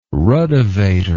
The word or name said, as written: ruddevator